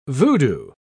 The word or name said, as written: voodoo